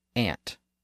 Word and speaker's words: aunt: Think about the pronunciation of the word spelled a-u-n-t. Some people say aunt, and some people say aunt.